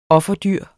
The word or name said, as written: offerdyr